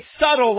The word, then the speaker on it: subtle: Very subtle, like Satan. Subtle. The B is silent.